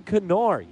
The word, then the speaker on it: Kennard: The story out of this one, Brandon Kennard, you pronounce the K, and there's a reason for it.